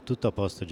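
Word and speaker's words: Tutto apposto: Ce film est un documentaire réalisé par la jeune cinéaste Chloé Lecci Lopez, il s'intitule Tutto Aposto Gioia Mia, qui en bon italien, se prononce eh, Tutto a poste, Jeremy.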